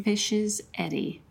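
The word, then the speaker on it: Fishs Eddy: Fish's Eddy. Did I say it correctly? – Fish's Eddy. Yes.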